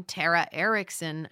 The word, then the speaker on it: Tara Erickson: Yes, it's my name, Tara Erickson. I just thought I would spell it out. Sometimes I say my name too fast and people are like, Terry, Ari, Erickson.